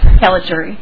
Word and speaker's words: Caligari: It's pronounced just like it's spelled, Caligari. – That's what I was going to guess. – Mr. Chair, members, my name is Hillary Caligari, and I'm an assistant Hennepin County attorney.